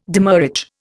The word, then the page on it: demurrage: Indian